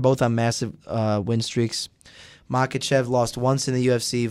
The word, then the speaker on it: Makhachev: Versus number two, pound for pound, Islam Makhachev. They always say it. I don't even know. Actually. – I pronounce it the John Anikwe. He knows. He says Makhachev.